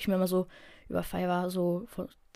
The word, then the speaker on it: Fiverr: Ähm, wie gesagt, F-I-V-E-R-R, ich habe das Wort Fiverr ausgesprochen.